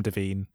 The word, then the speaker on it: Devine: I say Devine because it's like Kevin Devine, who I'm wearing his t-shirt right now, who's one of my favorite singer-songwriters, and I assumed it was like that, but it may well be Devine.